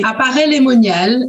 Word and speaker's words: Paray-le-Monial: If I'm saying it right, Paray-le-Monial. Is that right? – Apparaît-le-Monial, exactly.